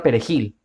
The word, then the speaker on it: perejil: que hablen la palabra perejil. ¿Y por qué hablen la palabra perejil? Porque los haitianos no podían pronunciar esa R.